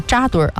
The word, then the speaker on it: 扎堆: 先更正一个读音啊，刚才在财经态度里面是扎堆啊。